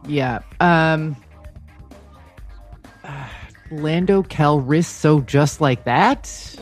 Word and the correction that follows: Calrissian: You kind of screwed up the pronunciation, but you got the answer. Lando Calrissian, just like that is.